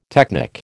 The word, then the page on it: technic: American English